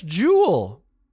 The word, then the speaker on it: Joule: The correct answer is C, it's Joule. That's spelled J-O-U-L-E-S. It's not the Who Will Save Your Soul singer.